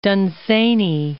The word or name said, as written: Dunsany